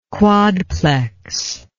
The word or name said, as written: quadplex